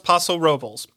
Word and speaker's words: Paso Robles: And I know it should be Paso Robles, but no, it's Paso Robles. It is pronounced Paso Robles.